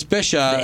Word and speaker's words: Bisaha: When I you have a last name last like Bisha, and there's an extra A in there, and I have to tell everyone, hey, it's, you know, it's Bisha. – The A is silent and stupid.